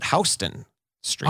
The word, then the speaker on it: Houston: But they pronounce it Houston Street.